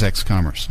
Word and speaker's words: X.Commerce: And my first question for you today, is what is X.Commerce? – Yeah. And as a small side, we usually pronounce the dot, X.Commerce.